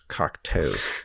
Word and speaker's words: Cocteau: And by the way, it's pronounced Cocteau. It's like, do you know how to pronounce this?